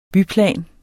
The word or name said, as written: byplan